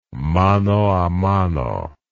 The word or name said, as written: mano a mano